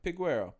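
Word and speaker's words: Peguero: Did I say that right? Yeah. – Piguero.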